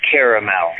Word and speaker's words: caramel: The sweet that you eat is correctly pronounced caramel.